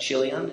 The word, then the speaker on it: Chilion: Even though it looks like Chilion, it's pronounced with the K sound.